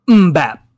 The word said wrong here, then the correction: Mbappe: Mbappe. – Kylian Mbappe.